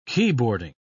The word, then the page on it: keyboarding: British English